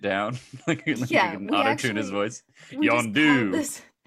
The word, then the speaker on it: Yondu: Also, the funniest thing about Sylvester Stallone being in the movie is that everyone calls Yondu, Yondu, and then Sylvester Stallone at the end calls him Yondu. Like... It's just got the emphasis on the wrong syllable.